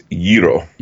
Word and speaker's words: gyro: Actually, Pete is pronouncing it wrong also. – Yeah, how is it? It's gyro. – Gyro? – That's the Greek.